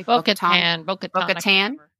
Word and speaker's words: Bo-Katan: And it was so great to see the other Mandalorians, especially Katee Sackhoff as Bo-Katan. – Yeah. – Am I pronouncing that correctly? – Bo-Katan.